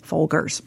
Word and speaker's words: Folgers: always Folgers with the hard G, Folgers.